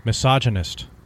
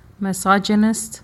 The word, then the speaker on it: misogynist: The American pronunciation of? – Misogynist. – Misogynist. – Misogyny. Yeah. That's the American / correct way to pronounce it.